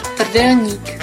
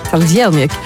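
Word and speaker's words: trdelník: Eigenlijk moet je het zo uitspreken. – Tardelnik. – Tardelnik.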